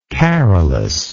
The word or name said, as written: carolus